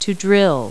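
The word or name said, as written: drill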